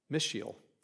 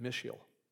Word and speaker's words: Mishael: Mishael is how you say it, Mishael.